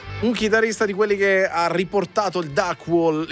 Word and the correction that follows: Duck Walk: Duck Walk, ho detto bene, però Walk, già negli anni 70 l'ha riportato in auge, un passo di Chuck Berry, che assieme a suo fratello hanno creato questo.